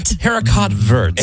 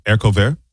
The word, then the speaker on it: haricot vert: Haricot vert. – That's green bean. – Yeah. – What is it? – Haricot vert. – Is that how you say it? – Yeah.